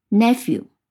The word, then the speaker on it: nephew: new 这个词里面的 e w 发音是一样的，所以呢，要记住 p h e w nephew。